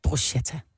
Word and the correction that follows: bruschetta: Hvordan udtales det så? – Bruschetta. – Med K? – Ja, CH giver K på italiensk. – Gør det, når du skætter? Ja. – Bruschetta.